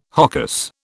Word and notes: hocus: British English

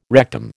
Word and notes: rectum: American English

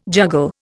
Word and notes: juggle: Indian

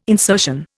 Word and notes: insertion: Indian

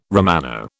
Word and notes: Romano: British English

